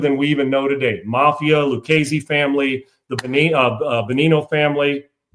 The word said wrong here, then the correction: Bonanno: That's Bonanno, I guess is how it's pronounced.